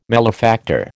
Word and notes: malefactor: American English

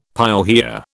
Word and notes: pyorrhoea: British English

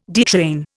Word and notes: detrain: Indian

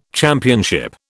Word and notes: championship: British English

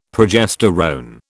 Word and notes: progesterone: British English

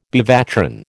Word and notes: bevatron: American English

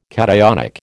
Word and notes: cationic: American English

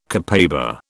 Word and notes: copaiba: British English

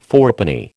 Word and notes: fourpenny: American English

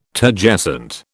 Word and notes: Turgescent: British English

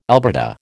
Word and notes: albata: American English

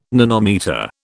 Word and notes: nanometre: British English

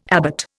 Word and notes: abbot: Indian

